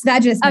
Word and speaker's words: vaginismus: And with the, is it vaginismus? Is that how you pronounce that? – Yes, vaginismus.